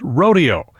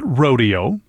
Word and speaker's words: Rodeo: Some people call it Rodeo, but Copland never pronounced it that way. The ballet company that premiered the work was sort of horrified to be doing something so American and lowbrow, as they saw it anyway. So they adopted a Spanish pronunciation to make it seem fancier to their fancy dance friends.